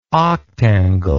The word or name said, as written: octangle